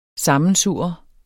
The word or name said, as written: sammensurre